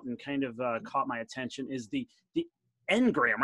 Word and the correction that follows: Enneagram: The Engram, how do you pronounce that, right? – Enneagram. – Yeah, Enneagram. Okay, right? Like I just can never get the syllables right.